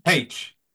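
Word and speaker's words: Paich: And I'm going to mispronounce his name, but David, is it Posh from Toto? – Page. Yeah.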